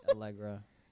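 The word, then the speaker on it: Aalegra: Allegra. That's what I say. It's like Allegra. You're right. Snow Allegra, like the medicine. – Yeah. – Algebra. – Snow as in ice snow and yep. Allegra as in medicine.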